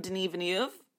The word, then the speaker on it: Denis Villeneuve: So Dune is the newest film from Denis Villeneuve.